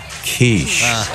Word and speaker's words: quiche: Well, why would they put quickie on the menu if you can't get one? – Bill, I believe that's pronounced quiche.